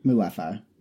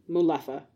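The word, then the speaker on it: Mulefa: The muffaletto? Muffaletto. – Muleffa. – Muleffa.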